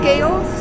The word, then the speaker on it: Gail: Gail Sredanovic.